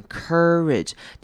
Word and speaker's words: courage: The ability to pluck up the courage， courage 这个字也是要特别注意的，勇气这个字它的 OU 这边要念成 courage，念成一个 R 的声音，有点像是 ER courage 这样子声音带过，然后后面的 A 会念成轻音，所以这个字是念成 courage courage，重音在开头的这个音节 courage。